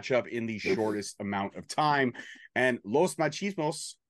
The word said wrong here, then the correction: Los Macizos: or Los, how do you say it again? – Macizos. – Los Macizos put Anton through the glass with a powerbomb.